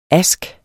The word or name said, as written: aske